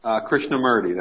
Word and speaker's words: Krishnamurti: Practice is deeply rooted in the core teachings of the Buddha and the work of Jay Krishnamurti. Did I pronounce that right? – Krishnamurti, that's right.